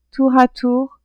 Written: tour a tour

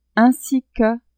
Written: ainsi que

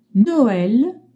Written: Noel

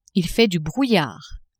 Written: il fait du brouillard

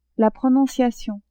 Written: prononciation

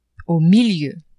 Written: au milieu

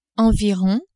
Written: environ

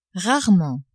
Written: rarement

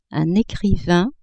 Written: ecrivain

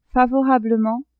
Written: favorablement

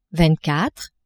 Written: vingt quatre

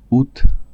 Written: aout

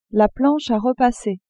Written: planche a repasser

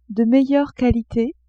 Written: de meilleure qualite

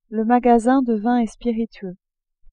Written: magasin de vins et spiritueux

